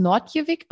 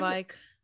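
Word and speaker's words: Noordwijk: Ähm, wie wird das eigentlich ausgesprochen? Wird das Nordjewig aus Nordjewig? Nordwijk. Nordwijk? – Soweit ich weiß, Nordwijk.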